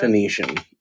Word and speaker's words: Phoenician: Phoenician? That's how you say that? – Phoenician. Yes, a Phoenician. So it's like a blind? – this fucking guy. Who invited him? – Yes, that is the correct pronunciation.